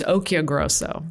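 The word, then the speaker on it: Occhiogrosso: Well, it's interesting because it's actually supposed to be pronounced Occhio Grosso. So when I go to Italy, you know. When I meet people who can speak Italian, like, you're saying it wrong.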